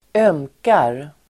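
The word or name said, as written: ömkar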